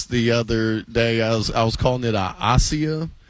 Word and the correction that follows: acai: Acai. – Oh, yeah, okay. – Everybody in L.A. knows how to pronounce acai. – Yeah, in Cleveland, not so much. – A-C-A-I.